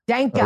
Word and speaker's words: Denka: His actual name is spelled D-E-N-K, and it's but it's actually rhymes with tank. So Denka. – Danka. Like, you know, okay. – Remember the coffee Sanka? So it's right. Just put a D in front of it.